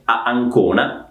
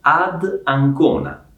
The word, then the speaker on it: ad Ancona: Migliore il suono, quindi non è a Ancona, che suona un po' male, ma è ad Ancona, ad Ancona, ok? Sono ad Ancona, vado ad Ancona.